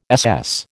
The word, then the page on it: SS: American English